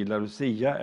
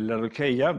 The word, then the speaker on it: Laodicea: Idag ska vi också gå till Uppenbarelseboken och vi ska gå till det sista brevet till församlingen i Laodicea eller Laodikeia, det kan uttalas på olika sätt.